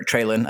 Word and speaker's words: Treylon: And Treylon Burks, or Treylon, I think that's how he wants it pronounced.